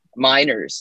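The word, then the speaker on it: Meinerz: I'm going to butcher his last name. Minerez. This is Miners. A center. Miners. Sorry.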